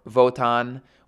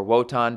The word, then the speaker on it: Wotan: Certainly at this period of time, but probably other deities such as Odin or Votan, or Wotan, depending on how you want to pronounce that word, in the past.